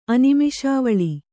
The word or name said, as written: ಅನಿಮೇಷಾವಳಿ